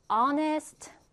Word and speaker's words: honest: 그러면 honest. 자, 사람은 뭐라 그러죠? 그치, person. An honest person. 이렇게 쓰는 겁니다. H를 발음하지 않기 때문에 앞에는 그쵸, on을 써준다라는 거예요.